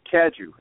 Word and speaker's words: Cadieux: It's Cadieux, actually.